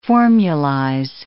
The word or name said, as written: formulize